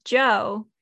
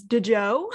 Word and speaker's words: DJO: And so I showed my mom, I was like, oh, this is Joe, DJO, which until I have did research on it, I have been pronouncing as DJO, which apparently is not how Joe Keery pronounces his music band self.